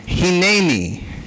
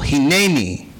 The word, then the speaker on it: Hineni: Say it with me, Hineni. The emphasis is on the middle syllable, Hineni.